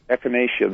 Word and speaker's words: Echinacea: Echinacea.